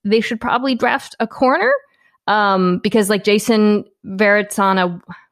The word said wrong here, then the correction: Verrett: it's Verrett. I never say his name right. Jason Verrett.